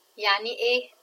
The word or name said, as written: ya3nieh